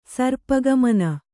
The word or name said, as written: ಸರ್ಪಗಮನ